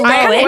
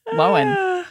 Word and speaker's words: Lohan: So it's Lowen. What's funny is that I read the articles before, or I like saw the headlines before I watched the TikTok, and I was like, Lindsay? Lindsay? I was like, what? How did we pronounce it that wrong? But yeah, I guess it's Lowen. – It's Lowen, which again, I think is kind of interchangeable.